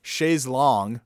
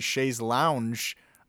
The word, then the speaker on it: Chaise Longue: And I heard this song that I add to the playlist, Chaise Longue, which thank you, Wet Leg, for teaching me the proper pronunciation, because I've been saying Chaise Lounge for years.